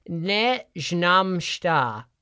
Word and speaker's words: ne znam šta: and it's ne znam šta.